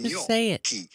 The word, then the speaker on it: gnocchi: We are looking at how to pronounce with a typical Italian pronunciation, gnocchi. Yes, double C-H in Italian sound like the letter K. So just replace those letters with a K, and it'll make a lot more sense.